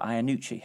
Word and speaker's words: Iannucci: Have I said that right? – Yes, you have. Although I've been to, we've done the premiere in one of the premieres in Toronto, and they have a different way of pronouncing it. They're like Iannucci. I say Iannucci, but I mean each to their own.